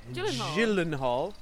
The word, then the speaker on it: Gyllenhaal: And Jake Gyllenhaal. I think it's a soft G, not a hard G.